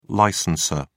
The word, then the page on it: licensor: British English